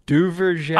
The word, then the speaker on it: Duverger: How do you say it? – Duverger. I think I'm saying that. That sounds exactly like it, in my opinion.